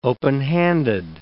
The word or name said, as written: openhanded